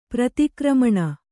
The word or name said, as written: ಪ್ರತಿಕ್ರಮಣ